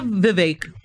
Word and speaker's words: Vivek: We have Vivek, rhymes with cake, to blame for it.